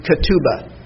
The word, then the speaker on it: ketubah: The first word is ketubah. I'll spell it for you. K-E-T-U-B-A-H. K-E-T-U-B-A-H.